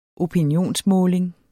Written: opinionsmåling

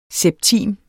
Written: septim

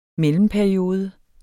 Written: mellemperiode

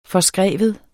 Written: forskrive

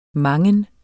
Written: mangen

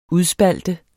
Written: udspalte

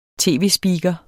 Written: tv-speaker

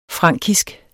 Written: frankisk

